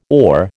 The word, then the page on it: oar: American English